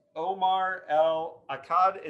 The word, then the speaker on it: Omar El Akkad: All right, joining me on Postcards from a Dying World is Omar El Akkad. Is that how you pronounce it? I'm terrible with pronunciation, but... – No, that was surprisingly well done.